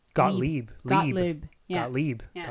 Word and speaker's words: Gottlieb: Got, Gottlieb. – Well, yes, Gottlieb. It's just the lib part.